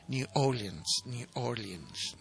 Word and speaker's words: New Orleans: En esa época, a partir de 1948, el estilo de jazz de New Orleans y el nuevo New Orleans, ¿no?, el acento en la primera, New Orleans Revival, eran muy populares en Gran Bretaña.